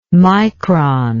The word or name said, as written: mikron